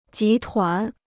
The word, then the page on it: 集团: jí tuán